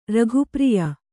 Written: ರಘುಪ್ರಿಯ

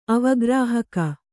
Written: ಅವಗ್ರಾಹಕ

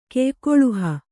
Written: ಕೆಯ್ಕೊಳುಹ

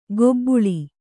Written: ಕೊಬ್ಬುಳಿ

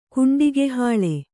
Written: ಕುಂಡಿಗೆಹಾಳೆ

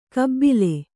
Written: ಕಬ್ಬಿಲೆ

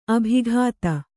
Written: ಅಭಿಗೀತ

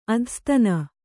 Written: ಅಧಸ್ತನ

